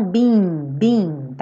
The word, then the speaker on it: being: Tem que ter esse som, being, tá? Porque senão fica be ou been, né? Então, being. São como duas sílabas. Being.